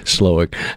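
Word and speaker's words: Slowik: My name is Buddy Slowick, and you know, I should probably spell that out because you would be surprised how often I get that name mispronounced, misspelled on everything. But it's S-L-O-W-I-K, Slowick.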